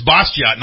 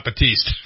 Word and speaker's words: Bastiat: It's Bastiat, not Batiste.